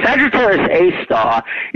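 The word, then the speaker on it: Sagittarius A*: The A has like an asterisk next to it, but it's actually pronounced A star. So Sagittarius A star is the object at the center of the Milky Way.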